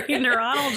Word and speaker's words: neuronal: The more we dwell on a memory or rehearse the specific events surrounding the memory, the stronger the neuronal? Neuronal. – Well, yeah. – Okay. – Yeah, you said it right.